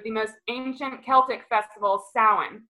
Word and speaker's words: Samhain: And for those of you who don't know, it's spelled S-A-M-H-A-I-N, which is like a hilarious way to spell things, but that's just the way that the Celts pronounce their